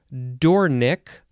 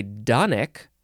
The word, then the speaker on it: dornick: The next word is dornick, or some people say donick, I guess. It is spelled D-O-R-N-I-C-K, which is why dornick makes more sense to me.